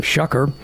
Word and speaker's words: Shough: We had a caller last hour say that the Saints made a big mistake in selecting this quarterback from Louisville, Tyler Shuck. Again, his name is spelled S-H-O-U-G-H, and you just would not want to pronounce it that way just naturally, but it's Tyler Shuck.